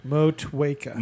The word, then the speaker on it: Motueka: How do I say it? I thought it's Motueka. – Motueka. – Motueka. – Okay, so it's like a W there.